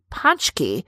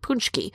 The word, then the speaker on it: paczki: Up here in Wisconsin, Michelle, there's a Polish bakery where people drive for hours from surrounding states just to enjoy Punchki. Some people even pronounce it Punchki.